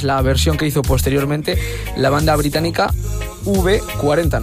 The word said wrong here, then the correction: UB40: no sé si pronunciar así. – UB40, sí, señor.